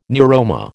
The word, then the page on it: neuroma: American English